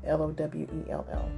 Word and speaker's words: Lowell: But, anyways, I found this poem by Robert Lowell. I hope I'm saying it right. L O W E L L.